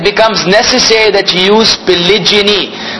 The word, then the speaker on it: polygyny: So there's a difference in pronunciation and a difference in spelling. Pronunciation wise, there's polygamy with a g versus polygyny with a j, with a g. And then the spelling, as we say, g-a-m-y, polygamy, g-y-n-y, polygyny. Polygyny.